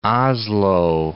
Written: Oslo

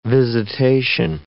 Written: visitation

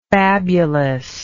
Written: fabulousness

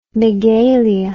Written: megalia

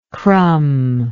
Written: crumb

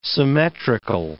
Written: symmetrical

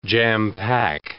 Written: jam-pack

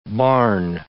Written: barn

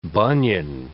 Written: bunion

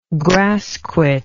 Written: grassquit

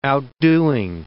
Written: outdoing

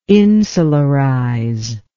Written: insularize

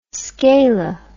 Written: scala

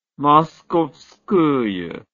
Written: Moskovskaya

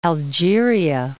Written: Algeria

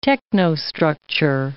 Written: technostructure

